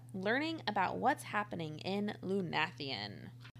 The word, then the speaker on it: Lunathion: And we're calling this episode Welcome to Lunathion, which we think is how you say it. – Yes.